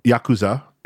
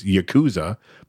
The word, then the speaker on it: Yakuza: She gets kidnapped by Yakuza, is how they say it in the yep. movie, which my American mm-hmm. dumbass always thought it was Yakuza, but Japanese put emphasis on different syllables.